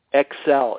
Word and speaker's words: Xcel: Xcel. It's spelled X-C-E-L.